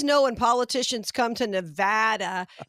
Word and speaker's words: Nevada: I don't know, out in Nevada. And I used to always call it Nevada, but I guess it's pronounced Nevada. And people that live there will get mad at you if you mispronounce it.